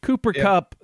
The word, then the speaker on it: Cooper Kupp: Cooper Kupp, the only one of three people in the NFL to get an MVP vote, as he said during the game.